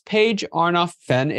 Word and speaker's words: Paige Arnof-Fenn: Miss Paige Arnof-Fenn is the founder and CEO of Mavens and Moguls.